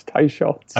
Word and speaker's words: Teicholz: I've known Nina for 10 years, and I realized last week that I mispronounced her name, but it's Teicholz.